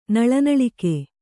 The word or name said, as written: ನಳನಳಿಕೆ